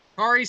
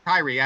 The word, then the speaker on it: Kairi: He's got Asuka, Kairi Sane. Did I say that right? – Kairi. – I meant Kairi. I knew it was right.